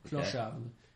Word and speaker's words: clochard: Clochard. Okay. – You don't really pronounce the D that much. Clochard.